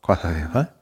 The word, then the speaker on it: Costa del Sol: Ähm, Club Costa del Sol, so spricht man das aus. Costa del Sol.